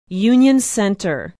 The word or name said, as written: union center